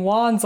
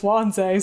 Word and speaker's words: Wanze: I was pronouncing the character's name Wands all last episode, and it's Wanze, so, like, who even knows?